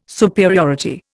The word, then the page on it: superiority: Indian